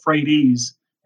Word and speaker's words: Frates: Now, our name can be said a couple different ways, you know, Frates, Frates, but they all said it correctly, Frates, and that meant they knew Pete.